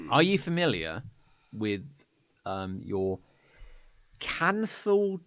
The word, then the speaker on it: canthal: I'll spell it for you. – Great. – C A N. – Yeah. – T H. – Yeah. – A L. – Canthal. – Canthal. – Yes.